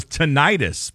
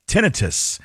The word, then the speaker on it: tinnitus: I thought it was tinnitus, but apparently it's tinnitus.